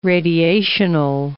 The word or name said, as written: radiational